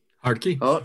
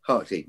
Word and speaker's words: Hartke: And I've got a big, now I don't know how you say it. Is it, it's written H-A-R-K-E. So it's a US brand. So H-A-R-T-K-E. – Harkey. – Harkey. Right.